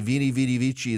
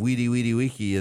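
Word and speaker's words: veni vidi vici: But I'm just not going to change it and pronounce it the proper Latin way. I say Vini Vidi Vici instead of Weedy Weedy Wiki as well.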